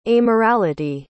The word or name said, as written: amorality